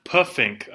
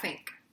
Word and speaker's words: Pfink: I really thought this was Rat Puffink a Boo Boo, because that's how it's spelled. – Yeah. – That's not how they say it. – No, it's Rat Fink. – Rat P-F-I-N-K a Boo Boo. – Like, fat. – Yeah. – P-H-A-T. – Right. – But P-F-I-N-K.